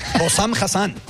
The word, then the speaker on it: Hossam Hassan: Vamos a escuchar al entrenador egipcio, Hossam Hassan o algo así. – Hossam Hassan. – Hossam Hassan, disculpe.